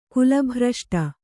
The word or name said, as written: ಕುಲಭ್ರಷ್ಟ